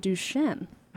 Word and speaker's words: Duchenne: So we're going to start out with the science of muscles and Duchenne.